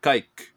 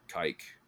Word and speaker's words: caique: C-A-I-Q-U-E. A light boat or skiff propelled by one or more rowers. – I imagine there's a horribly butchered anglicized word way to pronounce that, which would be like cack or kike. – Yeah, I would say kike is, or kike would be how I pronounce that.